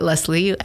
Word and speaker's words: Leslie: So I actually got the nickname IE because I spell it Leslie, L-E-S-L-I-E.